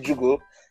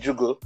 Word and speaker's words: Juugo: You can find me on Twitter at Nnamdi Jugo. Actually, you can find me everywhere at Nnamdi Jugo. So it's Nnamdi and J-U-U-G-O.